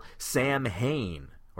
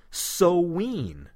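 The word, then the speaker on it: Samhain: But here there's a little sidebar that it's spelled the same, Sam Hain, but here they say it's pronounced Soween, as if it's spelled S-O-W-W-E-E-N.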